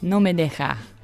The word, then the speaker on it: No me deja: No me deja.